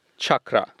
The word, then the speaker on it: chakra: It is certainly not chakra or chakra. Uh-huh. It is chakra. – Chakra. – Chakra. Uh-huh. There is a distinct R, and it's almost like my uncle Chucky is playing with chakra. That's kind of the pronunciation.